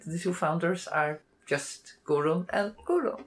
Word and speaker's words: Guðrun: The name is difficult to pronounce in English. It's Gudrun and Gudrun. It's spelled with the Faroese letter et, is silent. So internationally, typically people will say Gudrun and Gudrun, but actually it's Gudrun and Gudrun.